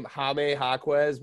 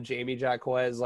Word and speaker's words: Jaime Jaquez: So I guess you pronounce this guy's name Jaime Jaquez, but I'm just going to call him Jamie Jaquez like for the rest of his life because that's what I started calling him.